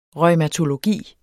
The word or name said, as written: reumatologi